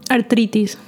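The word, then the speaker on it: artritis: Arthritis. – Arthritis. – Arthritis. So, yeah. So take out that H in arthritis and pronounce it like that.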